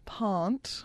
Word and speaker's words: Pant: I like how they say Pant. Like Arndt. Pant. – Well, did you want to check in with me how that's pronounced? – Yes, please. – Yes. – Pant.